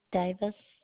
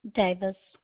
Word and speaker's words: Davis: The name on the account is Glenn Davis, G-L-Y-N-N. – Thank you. And I'm sorry, could you spell that first name again? – G-L-Y-N-N. – And the last name? – Davis. – Thank you. D-A-V-I-S? – Yes, ma'am.